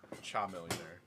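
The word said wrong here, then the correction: Chamillionaire: It's pronounced Ka Millionaire. Like the lizard.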